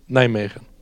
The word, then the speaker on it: Nijmegen: Nijmegen. Nijmegen. – Det lät tyskt. Men ja, Nijmegen. Om man har bott i Holland i 3 år.